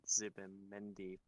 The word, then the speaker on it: Zubimendi: They've also been linked to Martin Zubimendi from Real Sociedad as well.